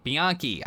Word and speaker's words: Bianchi: Founded in 2009, Blimp City offers a wide range of quality bicycles from reputable brands, Kona, Felt, Bianchi. I think I said that right in Italian. – Yeah.